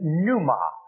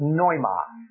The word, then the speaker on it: pneuma: The Greek word in the New Testament for spirit is in our English pronunciation, pneuma, P-N-E-U-M-A. The Greek would pronounce it noima. The P is silent like the T in fish. Pneuma.